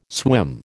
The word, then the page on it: swim: American English